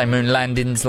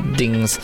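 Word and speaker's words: Moonlandingz: I say Moonlandings like that because it has got a G in it, but it's spelled with a Z. So I was like, Moonlandings. But it's dings.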